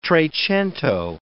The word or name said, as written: trecento